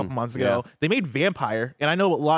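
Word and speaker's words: Vampyr: I think it's pronounced vampire. – No, it is. I don't like to call it vampire because there's a Y.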